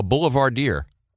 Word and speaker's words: boulevardier: Why do you call Richard Benjamin, I found this interesting, Buck, a boulevardier? Am I pronouncing that right? – Yes.